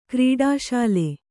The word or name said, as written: ಕ್ರೀಡಾಶೀಲೆ